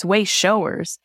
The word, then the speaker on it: Wayshowers: And I was laughing and said, I don't want to pronounce this wrong and say Wayshowers, you know, but you know, when you look at it quickly, it's like Wayshowers. No, it's Wayshowers.